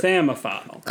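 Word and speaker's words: psammophile: But no, it's P-S-A-M-M-O-P-H-I-L-E. – Oh. – Samophile. – Okay, because it's got like psychologists.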